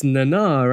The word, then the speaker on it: Nana: Notice we said Nana. – Yeah. I was going to say. We've been pronouncing that wrong. – Yeah. – I feel like most people pronounce it wrong. I've heard it mentioned, but other people say it before, and Jordan Hoffman brought it up when they did the DS9 reunion panel. It's Nana, right?